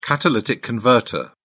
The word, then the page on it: catalytic converter: British English